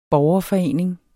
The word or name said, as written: borgerforening